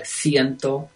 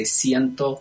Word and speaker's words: ciento: So within Castilian Spanish, so within Spain Spanish, they have things like ciento with a TH sound versus in Mexican Spanish and Latin American Spanish, they say ciento.